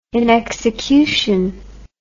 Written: inexecution

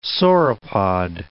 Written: sauropod

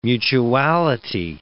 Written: mutuality

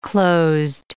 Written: closed

